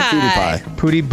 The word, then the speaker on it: PewDiePie: How do you pronounce it? – PewDiePie. – But it's spelled like PewDiePie. – It's spelled P-E-W. – P-E-W-D-I-E. P-I-E. – Like the Pew Institute.